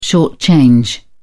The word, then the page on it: short-change: British English